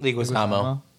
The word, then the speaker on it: Leguizamo: And I actually think, how do you say his last name? John Leguizamo.